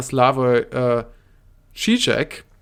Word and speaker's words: Slavoj Žižek: Dann ist mein Lieblingsphilosoph, sage ich jetzt mal, Slavoj Žižek, wenn ich den jetzt richtig ausgesprochen habe.